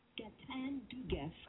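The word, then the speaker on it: Gaëtan Dugas: No, this is how you pronounce it. – Gatan Dugas.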